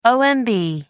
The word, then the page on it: omb: American English